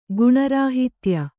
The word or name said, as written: ಗುಣರಾಹಿತ್ಯ